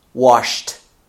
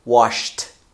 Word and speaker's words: washed: So when you do sh, and then, consequently, when you add ED to the end of this verb, you should pronounce it with a T sound, a T sound, okay? So, wash in the past becomes washed, washed.